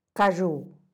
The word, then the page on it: kaxhu: Teotitlán del Valle Zapotec